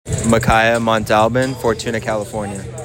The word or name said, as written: Micaiah Montalbin